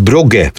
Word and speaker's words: Brugge: Rzeczywiście, bo nie zauważyłem, że tu jest U, więc Brugge w takim razie.